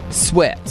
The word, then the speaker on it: Swett: The entire town of Sweat, South Dakota is for sale. – Maybe... – it's spelled S-W-E-T-T. Sweat.